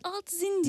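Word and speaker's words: Altzine: Ee, öykü deneme ve eleştiri yazıları alt Altzain. Doğru mu telaffuz ettim? Zine değil değil mi? Zain olarak. – Biz alt zin diyoruz. Zin, peki.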